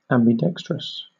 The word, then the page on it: ambidextrous: British English